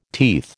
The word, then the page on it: teeth: American English